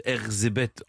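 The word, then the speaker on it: Erzsébet: Elisabeth, qui se prononce Erzébeth en hongrois, est fiancée à 11 ans au comte Ferenc Nadaski, un peu plus âgé qu'elle.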